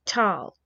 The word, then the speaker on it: Thal: Neander means new man, and the old German word for valley was Tal, T-A-L, or T-H-A-L, with a silent H.